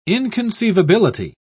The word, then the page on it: inconceivability: British English